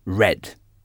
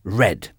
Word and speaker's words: read: Le passé de read est read. Read. Et là, il y a un petit problème parce que ça s'écrit exactement de la même façon que read. Mais la prononciation est différente. Donc, R-E-A-D se prononce read ou au passé read.